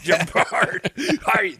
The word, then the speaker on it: Gerard: He's just trying to find his friend Gerard Butler. – Yeah. Now I just skipped right over. – Are you Gerard Butler? – Gerard. – Gerard. That's how it was pronounced back then.